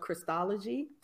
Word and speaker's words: Christology: She's also an instructor of Christology. Did I say that right? – Yes.